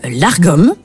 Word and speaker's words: lagom: Il y a un mot qui m'intrigue, dont j'ai entendu parler, c'est le lagom. Qu'est-ce que c'est alors le lagom, qui se prononce même largom, mais bon, lagom ça va quand même être plus joli, euh,